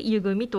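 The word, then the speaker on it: 井組: で、これも先ほど、言ったように、この湯というものは井戸の井と書いて、組で湯組というふうに読むんですけれども、その湯組を作ったということです。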